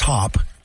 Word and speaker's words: Hopp: The guys from, I believe it's pronounced Hop, H-O-P-P.